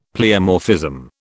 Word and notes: pleomorphism: British English

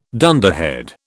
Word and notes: dunderhead: British English

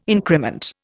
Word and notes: increment: Indian

